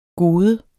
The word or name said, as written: gode